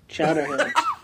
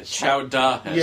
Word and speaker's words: chowderhead: Chowderhead. – Not chowderhead. Chowdahead. – Yeah, that's how you pronounce like that.